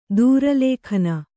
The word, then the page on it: ದುರಾಲೋಕನ: durālōkana